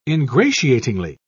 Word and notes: ingratiatingly: British English

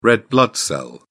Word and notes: red blood cell: British English

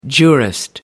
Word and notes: jurist: American English

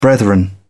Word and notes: brethren: British English

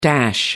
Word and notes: dash: American English

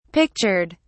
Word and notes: pictured: British English

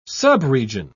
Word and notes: subregion: British English

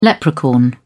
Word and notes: leprechaun: British English

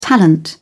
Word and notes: talent: British English

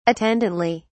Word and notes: attendantly: British English

